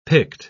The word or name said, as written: picked